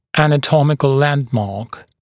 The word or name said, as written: anatomical landmark